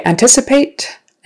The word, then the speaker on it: anticipate: Okay, anticipate. Anticipate. The accent is on the second syllable.